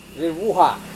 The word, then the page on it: vevuha: Serewen